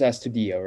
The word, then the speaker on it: Astudillo: And so they put up Williams Astudillo, right? He's this big, fat Venezuelan guy. He's a position player. He's good pronunciation. – That was very good.